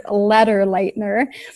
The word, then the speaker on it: Lederleitner: It's Lederleitner. It's spelled L-E-D as in David, E-R-L-E-I-T as in Teresa, N as in Nancy, E-R, at Wheaton, Wheaton.edu.